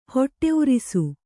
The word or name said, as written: ಹೊಟ್ಟೆಉರಿಸು